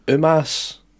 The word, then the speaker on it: Omos: I think it was Omos. – Mm-hmm. – Something like that, but now they're going Umas. – Are they? – Yeah, like, they made a point of saying it 20 times last night, Umas, and I'm like, what? – Umas. – How did you used to say his name? I can't remember. I think Omos. – It was Omos, or something like Omos. That, but they're going Umas now, and it's freaking me out.